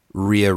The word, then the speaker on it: Rhea: Rhea Ripley? – Rhea Ripley.